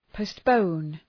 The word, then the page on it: postpone: pəʋst’pəʋn